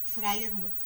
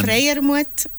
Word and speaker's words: Freiermuth: Freiermuth en alemán. Freiermuth en suizo alemán, sí.